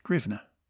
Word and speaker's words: hryvnia: Hryvnia is a transliterated name, as the Ukrainians use the Cyrillic alphabet. It should be pronounced grivna.